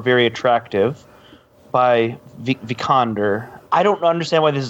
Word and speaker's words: Vikander: So he said Alicia Vikander. So she plays Laura Croft. Vikander. Alicia Vikander. – There you go. Okay. Say it like that every time.